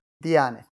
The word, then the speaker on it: Diyanet: How do you pronounce that again? – Diyanet, yeah.